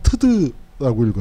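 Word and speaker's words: ㅌㄷ: 어, 북한식 발음으로는 트드라고 읽어요. 뭐냐면 타도 제국주의 동맹의 약자입니다.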